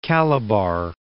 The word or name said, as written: Calabar